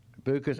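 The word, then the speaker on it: Bucas: Now, is it pronounced Bukas? – Correct. – Bukas and LAS.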